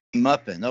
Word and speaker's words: MUFON: I know that many ufologists, including Muffin, who that is, am I pronouncing that right, Muffin, or is it Muffin? – Muffin. – MUFON. Okay, I know that MUFON. – MUFON's fine too.